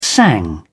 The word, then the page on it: sang: British English